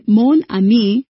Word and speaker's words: mon ami: In Mon Ami, the final consonant N of the first syllable Mon is pronounced as the initial consonant of the following syllable A.